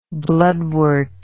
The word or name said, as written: bloodwort